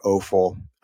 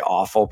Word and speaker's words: offal: Another name for it is offal or offal. We like to say offal because if you say awful, people are like, well, yeah, it's awful, but it's spelled O-F-F-A-L.